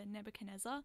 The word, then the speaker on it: Nebuchadnezzar: Nebuchadnezzar, which is Morpheus's hovercraft ship.